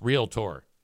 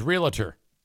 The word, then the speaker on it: realtor: Branjo says, I just gave a realtor orientation and told them how to pronounce realtor. Just two syllables. I for the longest time, I thought it was realtor. But it's you're right. It's realtor. Ladies and gentlemen, we are 38% away from a hype train.